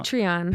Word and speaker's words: Patreon: Uh, hopefully, you follow us into and you said that I said it wrong. Pat- Patreon. – Patreon. Patreon. Yeah.